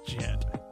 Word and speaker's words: jit: JIT. And also, I got to be careful because it rhymes with a word I know I can't say.